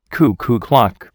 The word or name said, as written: cuckoo clock